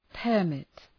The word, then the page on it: permit: pər’mıt